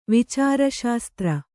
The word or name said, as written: ವಿಚಾರಶಾಸ್ತ್ರ